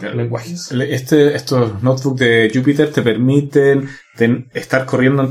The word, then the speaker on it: Jupyter: Lo llamo Jupyter porque eh, la palabra es tres sílabas, que es Julia, Python y R al final.